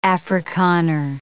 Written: Afrikaner

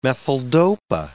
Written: methyldopa

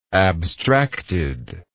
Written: abstractedness

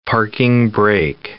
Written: parking brake

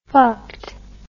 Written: fucked

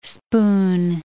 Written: spoon

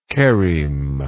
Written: cheremic

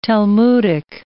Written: Talmudic

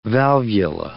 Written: valvula

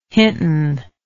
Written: hinton

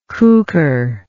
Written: cukor

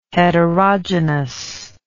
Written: heterogynous

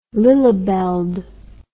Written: lillebaelt

